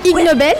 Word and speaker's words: Ig Nobel: Ig Nobel, ça s'écrit Ig Nobel. C'est comme deux mots, comme ignoble et Nobel.